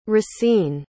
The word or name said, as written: racine